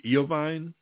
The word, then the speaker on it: Iovine: I have a very slight idea about Jimmy I think it's Iovine is how his name is pronounced.